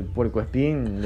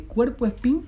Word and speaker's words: puerco espín: ¿Cómo se dice? ¿Cuerpo espín o puerco espín? Es puerco, porque es un puerquito.